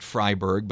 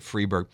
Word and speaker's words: Freiberg: I've also heard it pronounced Freiberg, but I've always said Freeberg.